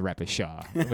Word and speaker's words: repechage: Repechage.